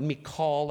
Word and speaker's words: Michal: Michal. Do you know what Michal, that's how it's pronounced in the Hebrew, do you know what Michal means in Hebrew?